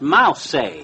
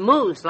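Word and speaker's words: mousse: That's pronounced mouse." – "No, it's pronounced moose, like Mrs. Whitney said."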